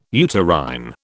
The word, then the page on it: uterine: British English